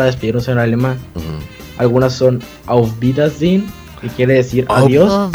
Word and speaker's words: Auf Wiedersehen: Y aquí te dejo la pronunciación que es Auf Wiedersehen.